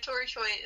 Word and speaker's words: Toritsui: So Tori Choi is spelled T-O-R-I-T-S-U-I underscore.